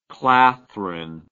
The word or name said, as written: clathrin